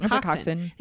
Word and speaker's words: coxswain: Coxswain. But oh, it's not. It's coxswain. It has a few extra letters in there, yeah. Which I do not pronounce.